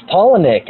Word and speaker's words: Palahniuk: And, like, if you can get that out with your dialogue in your books, like maybe some stuff like Chuck Palahniuk, who wrote Fight Club. – Sure, I know. It's Palahniuk, actually. – Yeah, because I'm not quite sure how to say it, but I guess I was in the ballpark, I guess. – Oh, it's... he was... His grandma and grandpa were named Paula and Nick.